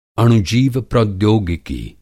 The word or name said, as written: अणुजीव प्रौद्योगिकी